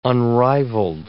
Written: unrivalled

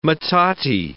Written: metate

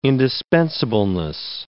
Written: indispensableness

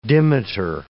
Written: dimeter

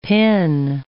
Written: pin

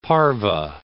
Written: parve

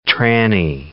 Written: tranny

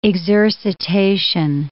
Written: exercitation

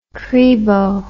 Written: cribo

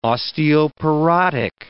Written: osteoporotic

